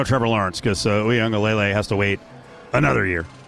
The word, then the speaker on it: Uiagalelei: The official pronunciation is Lele that I have read. Uiagalelei.